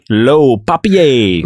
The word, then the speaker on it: Le Papier: How do you actually pronounce it? – Le Papier.